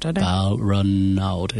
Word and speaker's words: Balranald: Can you pronounce it for me? Is it Balranald? Balranald in New South Wales, of the Mati Mati people.